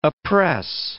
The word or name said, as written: oppress